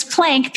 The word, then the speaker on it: Plank: which is Ronit Plank. So Ronit is spelled R-O-N-I-T, like Ronit, which is why people say Ronit all the time, but it's Ronit. And then it's Plank, P-L-A-N-K, like the yoga pose that I don't do enough of.